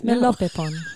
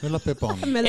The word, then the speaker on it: Melopepon: The song I've chosen is called Melopepon. Did I say that right? Yes, Melopepon. – Melopepon.